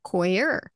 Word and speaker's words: coir: But as in like coy, coir.